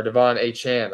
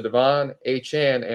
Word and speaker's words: De'Von Achane: Steve Campbell from Twitter asked, or Devon Achane, I'm sorry, correct myself. We had a corrector from Achane himself who said that's what he prefers, the pronunciation of it. So Devon Achane and Raheem Mostert are available in my league.